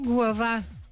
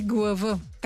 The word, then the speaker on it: Глава: Село Глава. Правилно съм сложила ударението, но някои му казват и Глава.